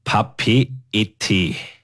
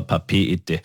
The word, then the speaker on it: Papeete: Sie hat aktuell knapp 190.000 Einwohner und der Hauptort ist Papeete, spricht man es, glaube ich, aus. – Ja, ich denke, das ist richtig, ja. – Schreiben tut man es wie so Papeete, aber Papeete.